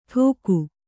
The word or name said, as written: ಠೋಕು